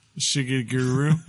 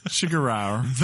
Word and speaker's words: Shigeru: Shigeru. Shigeru.